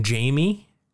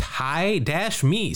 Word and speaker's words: Jaime: I called him Jamie, and I was corrected by Brad when he was on about the show. And on the back of this card, it says, Jaime's name is pronounced Hi-Me, Spanish for Jim.